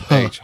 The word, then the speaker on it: Royce: Is it Hoist? It's Hoist, right? – It's Hoist, yeah. All of their names start with R, but it's pronounced Hoist.